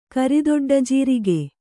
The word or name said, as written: ಕರಿದೊಡ್ಡಜೀರಿಗೆ